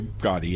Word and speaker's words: Gadde: Gaddi, G-A-D-D-E? – That's probably Gaddi, yes.